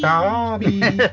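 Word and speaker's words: Zarbi: Is it the Zabi? – Yeah. – Say it properly. Zabi! – Zabi!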